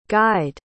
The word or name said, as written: gide